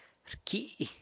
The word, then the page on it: rcyi: sanlucasquiavini